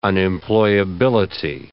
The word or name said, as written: unemployability